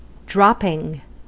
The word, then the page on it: dropping: American English; /ˈdɹɑp.ɪŋ/